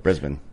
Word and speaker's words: Brisbane: Brisbane.